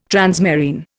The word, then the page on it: transmarine: Indian